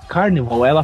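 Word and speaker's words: Carnivàle: É Carnivale, cara, ou Carnival em inglês, né? – Ah, muito boa, cara. Seria, que seria a tradução direta. A tradução não, né? A pronúncia certa.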